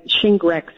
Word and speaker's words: Shingrix: So the new Shingrix vaccine, Shingrix is how they pronounce it,